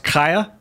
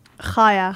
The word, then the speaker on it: Chaya: It's spelled Chaya, but it's pronounced Kaya. – Chaya. – Chaya. – Yeah. – It's more like H-A-Y-A with a... – C-H-A-Y-A.